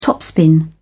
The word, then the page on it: topspin: British English